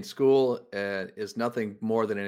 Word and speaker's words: Sheol: And they send us to this place called Sheol, pronounced Sheol in Hebrew, which means place of the dead.